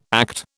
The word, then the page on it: ACT: British English